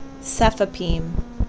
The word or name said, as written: Cefepime